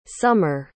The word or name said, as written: somer